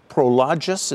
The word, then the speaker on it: Prologis: I drove out Route 80 today, and there was a big, last night, and there was a huge new Prologis, is that how you pronounce it? – Mm-hmm. – Prologis warehouse. – Yes.